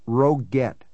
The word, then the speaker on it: Roget: He pronounced it Roguette, with a hard G.